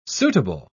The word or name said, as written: suitable